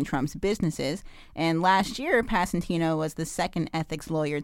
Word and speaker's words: Passantino: Passantino. I hope I'm pronouncing that right. – How's that spelled? – P-A-S-S-A-N-T-I-N-O. Passantino. – Passantino. – Okay, great. – You got it.